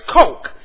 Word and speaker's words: choq: Now the Hebrew word there is coke, C-H-O-Q, coke, not coke like you drink. That's the way it's pronounced. Coke, C-H-O-Q.